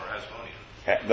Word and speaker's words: Hasmonean: Now, this man, Mattathias, pronounce that, if you will. Hasmonea. I have that right, and when I go to say it, it don't come out. – Or Hasmonean. – That's the word I'm more familiar with, Hasmonean.